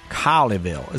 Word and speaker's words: Colleyville: I would slow it down and have a little more of that Texas drawl Colleyville. – In there. – Colleyville.